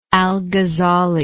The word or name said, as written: alghazzali